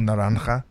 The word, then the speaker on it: Naranja: So the main area that we saw of growth in is literally south of Kendall, which is an area called Naranja. And we have seen... – Well pronounced. – Thank you. – For a guy with the British accent. You even got the "ch" in there.